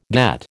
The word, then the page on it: get: American English